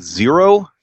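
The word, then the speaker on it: Xero: I will start with Zero, spelled with an X.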